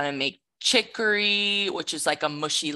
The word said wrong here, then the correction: khichdi: And khichdi. I pronounced it wrong.